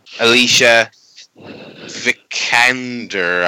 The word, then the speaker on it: Alicia Vikander: Starring Alicia Vikander, I think. Is that how you pronounce that? – Yeah.